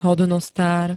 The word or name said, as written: hodnostár